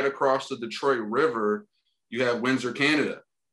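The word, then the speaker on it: Détroit: And fun fact, Gabriel, I don't know if you know, the Detroit French, I think it's pronounced Detroit.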